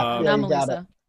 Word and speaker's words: Anomalisa: Anomalisa, am I pronouncing that correctly? I feel like I'm going to mispronounce everything. – Yeah, Anomalisa.